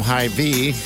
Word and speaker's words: Hy-Vee: I'm also going to set her up with flowers, some chocolates, and a charcuterie board from Hy-Vee.